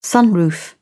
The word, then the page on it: sunroof: British English